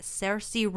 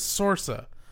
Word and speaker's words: Saoirse: And all the while, he is also romancing a girl named Agatha, played by Saoirse Ronan. – Is that how you say her name? Mm-hmm. I always thought it was Sorsa, but I don't think I've ever heard anyone say it until today.